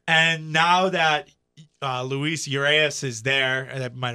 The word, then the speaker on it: Urias: Did I butcher his name? – Urias. – Urias? – Said, yeah. – Okay. I said that's Urias. Urias.